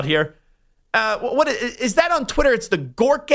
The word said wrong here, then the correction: Grok: AI. Is that how you say it correctly? Do you have any idea how to pronounce Grok. – That? Grok? – It's like Gronk without the N.